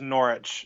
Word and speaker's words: Norwich: That W is silent. It's just Norwich.